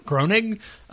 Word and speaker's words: Groening: Groening? – Groening.